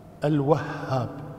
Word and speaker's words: الوهاب: الواو ليست مشددة، فتحة، الشد على الهاء، الوهاب، الشد على الهاء وليست على الواو، عبد الوهاب الواو مفتوحة لكن الشدة تكون على الهاء، وهاب وليس على الواو.